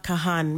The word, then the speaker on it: Kahan: Number 17, Doors Noah Kahan. Make sure you pronounce the H, okay?